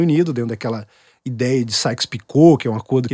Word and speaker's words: Sykes-Picot: É Sykes-Picot.